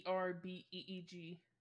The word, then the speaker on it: Verbeeg: Is that how you pronounce that? Furbeeg? – Bugbear? Yes. – No, it's VERBEEG.